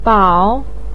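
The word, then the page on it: bao3: tone 3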